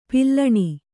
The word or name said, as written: ಪಾಲುಣ